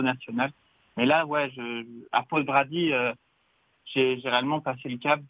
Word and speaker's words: Poděbrady: Alors, je le prononce mal, c'est une ville tchèque dont on peut le prononcer à la française. Alors, on va dire Poděbrady. Voilà.